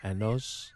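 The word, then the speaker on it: años: Now, if you said the way you pronounced A-N-Y-O-S, you're likely to get us arrested. So let's try that again. 100. – Ano. Anos. – Anos. All right. – Anos. – Because we don't want to say it like Borat.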